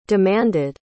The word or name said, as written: demanded